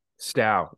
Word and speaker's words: Stough: Stough, rhymes with dairy cow.